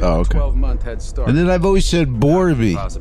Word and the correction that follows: Barbie: Is that how you say Borby? – Borby? – Oh, it's Barbie. Oh.